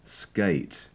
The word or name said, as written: skate